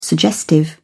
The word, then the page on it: suggestive: British English